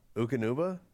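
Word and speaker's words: Eukanuba: Ukanuba? – Is that how you say it? – Yeah, the dog.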